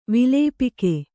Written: ವಿಲೇಪಿಕೆ

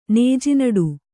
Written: ನೇಜಿನಡು

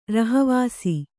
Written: ರಹಿವಾಸಿ

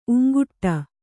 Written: ಊಂಕಿಡು

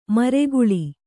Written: ಮರಗಾಳಿ

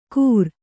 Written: ಕೂರ್

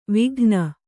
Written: ವಿಗಾನ

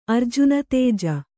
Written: ಅರ್ಜುನತೇಜ

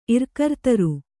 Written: ಇರ್ಕರ್ತರು

